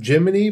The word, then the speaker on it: Gemini: I don't like that they call him Jiminy, but it's spelled Gemini.